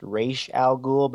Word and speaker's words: Ra's al Ghul: I think I heard him on a podcast say it's pronounced Ra's al Ghul, but they've never done it that way in the movies.